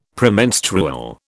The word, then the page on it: Premenstrual: British English